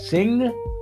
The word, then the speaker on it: Singh: I know his first name's Amrit, spelled A-M-R-I-T. And I'm going to take a chance that the second name's pronounced Singh, with a silent H, but it's spelt S-I-N-G-H. And the last name, I'm going to guess, is Reinsch. Probably way off. Spelt R-E-I-N-S-C-H.